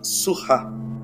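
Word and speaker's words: Seurat: do Jorge Surra, Surra né, que é a pronúncia correta.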